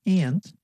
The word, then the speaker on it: aunt: ส่วนคนอเมริกันทางฝั่งตะวันออกเฉียงเหนือของประเทศนะคะจะออกเสียงคำว่าป้าว่า aunt ค่ะฟังตัวอย่างนะคะ People say the word aunt like aunt.